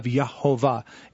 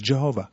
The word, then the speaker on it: Jehovah: And if you template those together, you have Yehovah. Adonai and Yahweh together with the hard J sound is Jehovah.